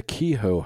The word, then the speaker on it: Kehoe: We have in Savannah, Georgia, the Kehoe House. That's what I'm going to pronounce it as. K-E-H-O-E. – That's right.